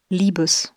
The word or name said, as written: liebes